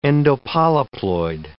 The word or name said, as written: endopolyploid